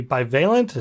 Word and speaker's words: bivalent: The CDC has a report about the bivalent.